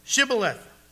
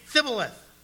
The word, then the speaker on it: Shibboleth: And if he said, "No," they would say to him, "Then say, Shibboleth." And he would say, "Thibboleth." For he could not pronounce it.